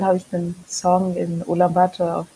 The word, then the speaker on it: Ulaanbaatar: Ulaanbaatar. – Ja. – Ulaanbaatar.